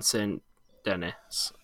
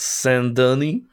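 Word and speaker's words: Saint-Denis: I think you prefer not find it's pronounced Saint Denis. – Yeah, well, I'm not saying it like that because it's spelled Saint Denis, and it's really pronounced Saint Denis. – So the people who have named the town need to spell it correctly. – They have spelled it correctly. It's French, Saint Denis.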